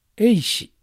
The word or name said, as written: Eishi